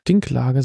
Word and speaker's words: Dinklage: Dinklage, so spricht man das auf.